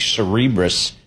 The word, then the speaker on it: Cerebras: Cerebras, I think, is kind of how we figured out how to pronounce it.